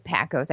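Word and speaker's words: Packo's: I didn't get her again to get to take her to Tony Paco's, which I said it right, Paco's. I get teased here because I say Paco's because, you know, I'm Canadian, and you know, it's like you got to take ever someone.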